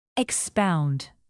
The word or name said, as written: expound